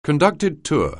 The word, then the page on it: conducted tour: anglais